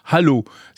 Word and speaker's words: HaLow: Ce nouveau super Wi-Fi euh, de Morse Micro est baptisé Wi-Fi Halo. Ça s'écrit H-A-L-O-W.